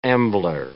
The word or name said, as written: ambler